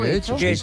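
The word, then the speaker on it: Getxo: En Guecho, que nunca sé cómo se pronuncia. – ¿Guecho? – Guecho. Yo creo que lo pronuncio bien.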